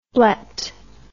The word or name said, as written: blet